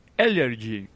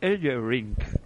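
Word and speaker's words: Ellering: Eh, de Autos of Pains eh, con su manager, ojo a la pronunciación, ahí viene el eh, redoble de, tambores. Paul Ellerring. E, e, Ellerring. Ring.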